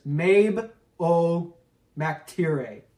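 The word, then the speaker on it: Mebh Óg MacTíre: Mabe, it's pronounced Mabe Og MacTire. That, at least that's how she pronounced it. She spoke her name before. She says it's Mabe Og MacTire.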